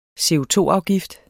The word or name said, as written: CO₂-afgift